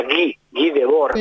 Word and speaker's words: Guy Debord: Y lo voy a pronunciar bien, pues se escribe Guy Debord, Guy Debord. Y bueno, me han dicho que se pronuncia Guy Debord, el autor de La Sociedad del Espectáculo.